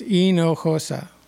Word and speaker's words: Hinojosa: Okay, so simply it's four syllables. H is silent, J is an H, so it's Hinojosa.